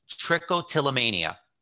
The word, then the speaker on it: trichotillomania: And then, even on top of that, if that wasn't bad enough, you had trichotillomania, a hair pulling disorder that left you with bald patches, right? – Correct.